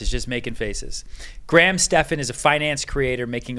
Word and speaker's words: Stephan: I said your name right? Right? – Stephan. – Yes. Graham Stephan.